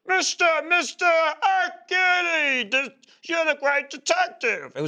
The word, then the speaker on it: Hercule: Well, no, because his name is Hercule. Oh. But it's pronounced Hercule. But people are like, hey, Hercule, you're Hercules.